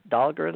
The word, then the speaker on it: Dahlgren: And that's, I guess it's still presently headquartered at Dahlgren, Virginia. How do you say that? Dahlgren? – Dahlgren, yeah.